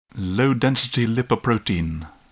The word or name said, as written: low-density lipoprotein